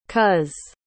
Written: cos

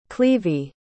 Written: clevie

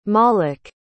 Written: maalik

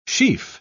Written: sheaf

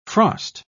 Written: frost

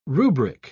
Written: rubric

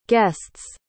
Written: guest's

